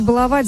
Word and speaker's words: баловать: Ударение в слове «баловать» в будущем изменится и будет падать только на первый слог, сообщил научный сотрудник Института русского языка имени Виноградова Владимир Пахомов.